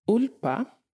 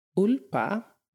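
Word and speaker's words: úlpa: And Ulpa, I'll say it again, Ulpa, basically a parka, even though it's again like a coat.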